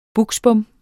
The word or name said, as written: buksbom